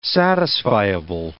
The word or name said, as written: satisfiable